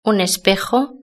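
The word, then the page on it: un espejo: Spanish (Spain)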